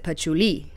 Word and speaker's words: patchouli: I did it all over Argentina as well, which if you are looking for patchouli, it's really cheap in Argentina, and you have to pronounce it patchouli with a definite accent on the end.